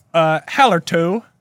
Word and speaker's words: Hallertau: Tettenang, Hallertau, Splatt, and Saz.